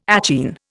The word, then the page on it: achene: Indian